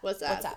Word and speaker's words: WhatsApp: Yeah, see, then that goes against my, like, theory that it was, like, sorry, I keep saying this wrong. What's up app? WhatsApp? – Oh, WhatsApp. – WhatsApp.